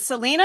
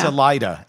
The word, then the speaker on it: Salida: Is it Salina or is it Salina? – Salida, S-A-L-I-D-A. – Really? See, I would have totally pronounced it the other way. – No, Salida, Colorado.